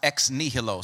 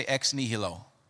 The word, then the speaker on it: ex nihilo: That new is called, or is pronounced, ex nihilo. Say ex nihilo.